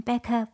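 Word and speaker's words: Bekah: Stuff, such a powerful sound, Consent featuring Becca by Saffron.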